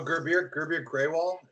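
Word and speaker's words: Gurbir Grewal: Oh, Gerbier Graywall, is that how you pronounce it? – Yes.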